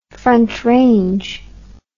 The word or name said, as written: frontrange